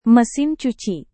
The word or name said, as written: mesin cuci